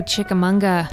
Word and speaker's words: Chickamauga: Chickamauga.